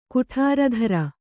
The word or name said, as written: ಕುಠಾರಧರ